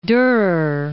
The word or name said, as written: Durer